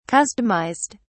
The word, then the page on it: customised: British English